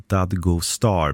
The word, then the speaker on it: Dadgostar: Efternamnet Dadgostar uttalas ungefär Dadgostar med betoning på första och sista stavelsen.